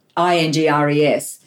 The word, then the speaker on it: Ingres: And he quoted those incredibly beautiful letters about, what was that one, Engre, I pronounced that wrongly, the artist, Ingres, spoke about something about this pestiferous world, and Lawrence repeats those words.